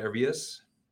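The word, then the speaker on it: Urías: Urias. – That's fine. I like how you say it better with the proper accent and pronunciation.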